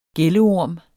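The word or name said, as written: gælleorm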